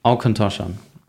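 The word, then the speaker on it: Auchentoshan: Aucontoshan. Ja. Der Aucontoshan Three Woods.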